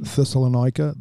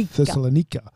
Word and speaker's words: Thessalonica: Aussies pronounce it Thessalonica. The Greeks say Thessalonica.